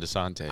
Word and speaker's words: DeSantis: Ron DeSantis. – Dang. He said it kind of differently. – DeSantis. – He almost got it right. Usually it's Ron DeSantis.